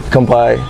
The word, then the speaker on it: Kanpai: Kanpai.